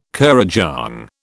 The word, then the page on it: kurrajong: British English